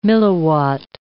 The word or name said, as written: milliwatt